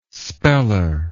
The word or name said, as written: speller